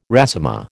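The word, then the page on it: résumé: American English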